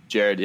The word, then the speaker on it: Jared: Is it Jared or Gerard? I've heard people pronounce. Jared, yeah.